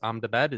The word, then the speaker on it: Ahmedabad: It's not Ahmedabad, it's Ahmedabad is the correct pronunciation, in the Narendra Modi Stadium.